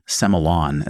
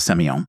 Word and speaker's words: Semillon: So I do carry a very, very early Hunter Valley Semillon, as they pronounce it in Australia, a Semillon.